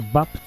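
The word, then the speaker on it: BAP: Diese Aussprache von Papa kommt aus dem Unkelerplatt, woher die Familie Niedecken stammt. Weil sie vom Kölschen Papp abwich, wurde Bapp zu Wolfgang Niedeckens Spitznamen.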